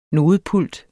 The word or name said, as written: nodepult